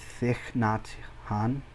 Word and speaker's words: Thich Nhat Hanh: And it's a really good, it's called How to Walk by Thich Nhat Hanh.